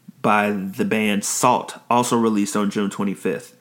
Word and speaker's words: Sault: No one is really sure how many members there are in Salt, and it's spelled S-A-U-L-T.